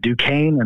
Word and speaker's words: Duquesne: Oh, we got to work on your guys' Missouri pronunciation, too. It's Duquesne and Lillard.